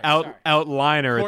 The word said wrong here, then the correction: outlier: It's outlier.